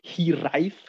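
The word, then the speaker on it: hiraeth: And he was searching for a strong word for our band name, and then he came to this, who's actually pronounced Hiraith with a TH at the end.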